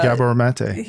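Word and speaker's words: Gabor Maté: Gabor Mate, is that how you say his name? – Yeah, Gabor Mate.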